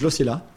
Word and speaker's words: Losilla: Uh, I don't even know if I should pronounce this French style or Spanish style. – Yeah. What is it? I think it's Losilla. Losilla?